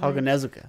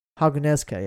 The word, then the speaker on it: Haganezuka: As you said, like Demon Slayer, Haganezuka, how do you say his Haganezuka. – Name? Haganezuka, yeah, sorry.